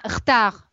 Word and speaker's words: retard: Зачеркните себе «р», вот это «э», первую «е» после «э». – «Хта», да, вы сейчас «хтар». – Да, она «хтар», «хтар». «Д» зачеркиваем, «д» на конце не читается.